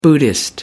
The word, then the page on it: buddhist: American English